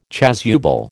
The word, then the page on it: chasuble: American English